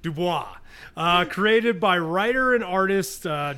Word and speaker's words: Dubois: How do you say it? – Dubois. – Dubois. – It's French.